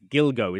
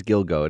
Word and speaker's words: Gilgo: I always mispronounce that word, but it's Gilgo, isn't it? – Yeah, I think it is Gilgo, and it sounds too fun for what the subject matter is.